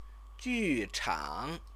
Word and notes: juchang: Chinese

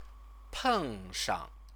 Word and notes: pengshang: Chinese

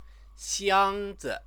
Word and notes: xiangzi: Chinese

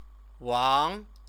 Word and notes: wang: chinese

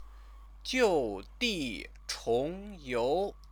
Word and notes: jiudichongyou: chinese

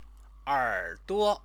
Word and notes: erduo: chinese

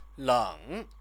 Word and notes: leng: chinese

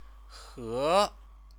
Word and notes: he: chinese

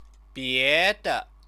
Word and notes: biede: chinese

